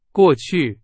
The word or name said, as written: 过去